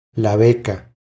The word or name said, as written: La beca